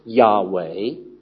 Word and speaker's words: Yahweh: And the same article goes on to say that the name is Yahweh, should be pronounced Yahweh, and should be used.